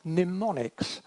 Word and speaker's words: mnemonic: They have memory aids or mnemonics. See if I can say that properly. It begins with an M and an N. Mnemonic.